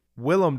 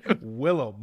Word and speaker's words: Willem: Is it Willem? – Willem Dafoe. My bad. Damn. Willem. My bad. My bad. Mr. Dafoe, I am very sorry for mispronouncing.